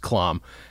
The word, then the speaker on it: Klamm: People think my last name is Clam all the time. And what complicates it further is that most people who have my last name pronounce it Clam. – Oh, really? – My wing of the family is the only ones who goes with the original German, which is Klom.